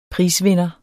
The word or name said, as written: prisvinder